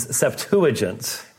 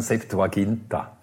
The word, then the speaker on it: Septuagint: Generally, the pronunciation is Septuagint. – Ja, gewöhnlich sagt man eben, wie er sagt, wir sagen Septuaginta.